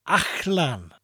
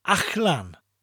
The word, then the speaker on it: allan: The Welsh word for out is achlan, spelt A-double-L-A-N, and pronounced achlan. Achlan. Achlan.